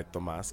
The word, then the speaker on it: Tomas: Yes, as he said, I am Tomas Reyna. It's spelled Thomas, but I go by Tomas because my father's name was Tomas, T-O-M-A-S. My mom thought she'd put a H in so it'd be silent, but...